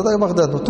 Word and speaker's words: بغداد: وببغداد بغداد تنطق هكذا.